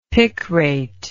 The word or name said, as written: picrate